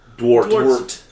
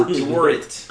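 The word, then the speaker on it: Dworet: Dwart. – Yeah. – Dwart. – I went to a Dwart. – College that sounded a lot like that. – Dwart.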